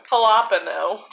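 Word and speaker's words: jalapeño: Jalapeno. For those of Jalapeno. – Still pronounce the J as an H, but also pronounce it wrong.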